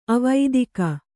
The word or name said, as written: ಅವೇದಕ